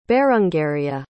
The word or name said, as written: berangaria